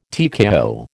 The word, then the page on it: TKO: American English